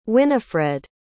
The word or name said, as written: Winnifred